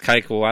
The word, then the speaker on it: Keuchel: So you proved to me by talking to Siri there on your old iPhone that his last name is Keuchel. I thought it was Kutchel or Kutchel. So either way, Dallas Keuchel,